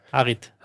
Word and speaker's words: Harit: Und dann irgendwann kam Hachid und hat das Spiel hat dann hat das, das Spiel hast du jetzt aber sehr gut ausgesprochen. – Heißt er nicht lang? Harit. Harit. Harit.